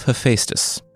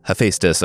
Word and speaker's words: Hephaestus: After that, it's back to the Monkey King, and then the first week of next month, it's the story of Hephaestus. Uh, Hephaestus, according to the pronunciation aids on the internet, or Hephaestus, according to everyone who reached out after I said Hephaestus on last week's episode.